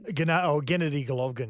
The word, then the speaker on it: Gennady Golovkin: Sats, it seems as though Father Time has caught up with Gennady Golovkin. That's how you say it? Gennady? Gennady Golovkin? – Gennady Golovkin, yeah.